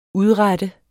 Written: udrette

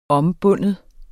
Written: ombinde

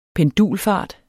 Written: PENDULFART

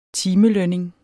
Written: timelønning

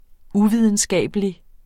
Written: UVIDENSKABELIG

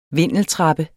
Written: vindeltrappe

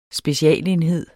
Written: specialenhed

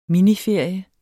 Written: miniferie

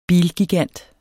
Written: bilgigant

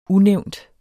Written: unævnt

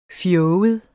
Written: fjoget

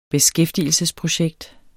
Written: beskæftigelsesprojekt

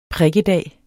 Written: prikkedag

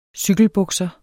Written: cykelbukser

